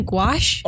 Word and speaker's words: gouache: Do you mean gouache? – Oh, yeah. – Guacamole. – I told you I was going to butcher it. – Definitely sounds like gouache.